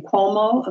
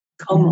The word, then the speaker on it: Cuomo: Cuomo of New York. Cuomo.